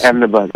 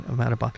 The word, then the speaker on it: Ahmedabad: I mean, obviously you're excited uh, and motivated by the story, and the and and uh, and being at the uh, uh, at Ahmadabad what yeah, else? Ahmedabad, yeah. I'm sorry. – I keep butchering that, Ahmedabad.